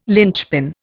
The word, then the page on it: linchpin: Indian